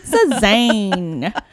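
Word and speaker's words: Cézanne: Also, I mispronounced Cezanne's name. I sounded like really like an American. – Cezanne. – But it's Cezanne. – She's written in the notes here. She said Cezanne like a dirty American.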